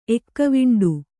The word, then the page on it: ಎಕ್ಕವಿಂಡು: Kannada